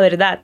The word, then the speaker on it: verdad: A primeira frase é dime la verdad, que significa me diga a verdade. Atenção à pronúncia da palavra verdade. O V tem som de B e o D no final é quase mudo.